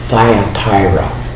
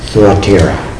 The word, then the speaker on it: Thyatira: And when we come to the third one, I think it's typically pronounced Thyatira. The Y there should be pronounced just the same as in Smyrna. Thyatira.